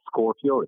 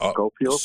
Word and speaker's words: Scopio: Scopio, it's not Scorpio, it's Scopio. – Scopio, I mispronounced it. – Scopio, from microscope.